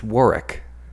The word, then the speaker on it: Warwick: It's pronounced Warwick. Fun fact. – Yeah, that's a fun fact. – Warwick Davis.